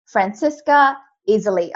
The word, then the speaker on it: Franziska Iseli: And with me today, I have yet another unstoppable leader, Franziska Iseli.